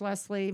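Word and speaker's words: Lesslee: They can always, I'm on Facebook and I'm on Instagram, Coach Leslie. My name is spelled different though. It's L-E-S-S-L-E-E.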